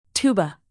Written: tuba

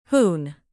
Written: hoon

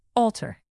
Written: altar

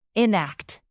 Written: enact

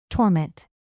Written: torment

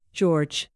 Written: george

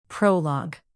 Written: prologue

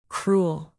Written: cruel

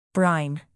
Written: brine

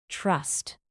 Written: trust

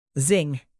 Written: zing

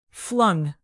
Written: flung

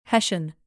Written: hessian

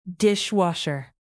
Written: dishwasher

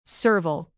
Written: serval

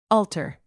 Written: alter

